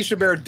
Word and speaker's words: Chabert: Lacey Chabert does have. – Oh, that's how you pronounce it? – Yeah.